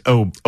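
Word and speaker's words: Åberg: Lucas Oberg. Do you like Oberg or Oberg? – Lucas. – I'm sorry, Ludwig. – Ludwig. It's Oberg.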